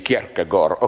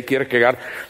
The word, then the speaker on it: Kierkegaard: I read in a biography of, how do you pronounce it correctly, Kierkegaard. Okay, we in the corrupted West say Kierkegaard.